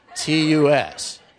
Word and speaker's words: Hubertus: As future reference, if anyone comes in here, you know, Garrison or somebody wants to make a local reference, it should be pronounced Hubertus. – Thank you very much. That's I want to say. Hubertus. Hubertus. Because Hubertus is actually something obscene in Latin. So you don't want to say that.